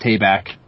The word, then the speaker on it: Tabak: It started out with the Wizards of the Coast rules manager, Matt Tabak, rhymes with payback, as far as I'm aware.